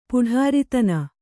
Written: ಪುಢಾರಿತನ

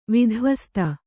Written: ವಿಧ್ವಸ್ತ

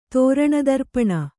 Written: ತೋರಣದರ್ಪಣ

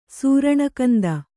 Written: ಸೂರಣಕಂದ

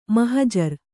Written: ಮಹಜರು

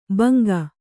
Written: ಭುಂಗಾ

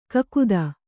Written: ಕಾಗದ

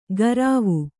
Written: ಕರಾವು

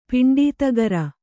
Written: ಪಿಂಡೀತಗರ

